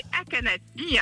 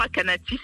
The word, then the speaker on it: Akanati: Motsé Akanati, hein. Motsé, c'est mon prénom, Akanati, c'est mon nom, A-K-A-N-A-T-I, voilà, tu sais tout, Claude.